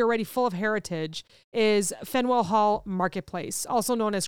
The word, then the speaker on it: Faneuil: This is like mentioned a lot, so I didn't know how to say it because it is spelled F-A-N-E-U-I-L. Fenwell.